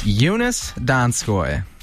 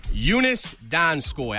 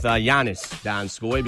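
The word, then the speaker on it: Joonas Donskoi: Yeah, it is Jonas Donskoy. – Jonas Donskoy. I would have went with Giannis Donskoy because my thoughts are just on the NBA.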